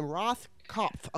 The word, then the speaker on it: Rothkopf: But I want to preface this with a tweet by uh, David Rothkopf. If I can't, Roth, I can't yeah. say his name. – Rothkopf.